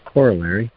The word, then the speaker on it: corollary: Yeah, I think that word's usually pronounced corollary.